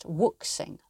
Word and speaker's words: wuxing: If you look at the letters strung together, W, U, X, I, N, G, you might think it's pronounced wuxing, like waxing. But it is in fact wuxing.